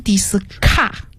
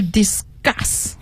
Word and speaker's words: discuss: 对，好，再往下一个呢是 discuss 就是讨论的意思，但是它的发音好多同学会念成 discuss，其实是 discuss，它 s 后面要发那个浊化的 g。 – 嗯，对了，g 的音，因为它是在 s 轻辅音后面的重读音节，它还要重读啊， discuss。